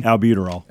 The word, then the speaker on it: albuterol: Albuterol.